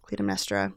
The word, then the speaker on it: Clytemnestra: Follows Electra, the daughter of Agamemnon and Clytemnestra. Clytemnestra. – I know you pronounced that in French because you were in a play about it. – Yeah, that's correct.